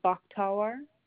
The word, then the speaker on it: Bakhtawar: It's under Bakhtawar.